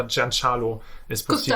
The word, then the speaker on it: Giancarlo Esposito: Also ich meine, er hat mal auf Twitter hat er seinen Namen ausgesprochen, irgendwie in einem Video, und da hat er Giancarlo Esposito.